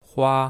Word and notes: hua1: mandarin

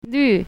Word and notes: lv4: mandarin

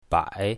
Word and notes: bai3: mandarin

